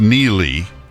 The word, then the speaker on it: Neagley: If you remember, if you read the Reacher books, she's a private investigator who is, now she is, but Frances Neely, spelled N-E-A-G-L-E-Y, but it's pronounced Neely, is a former U.S.